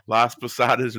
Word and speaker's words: Las Posadas: During the festival, holiday traditions of the world come to life around World Showcase, such as Las Pasoras at the Mexican Pavilion, the Canadian Holiday Voyagers in the Canadian... No, wait, sorry, I said that wrong. Las Posadas in the Mexico Pavilion.